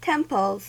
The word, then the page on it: temples: American English